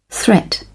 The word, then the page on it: threat: British English